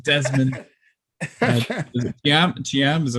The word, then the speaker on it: Desmond Chiam: When we just had an interview on with Desmond Chiam.